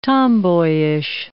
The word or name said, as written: tomboyish